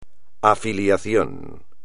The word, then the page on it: afiliación: espagnol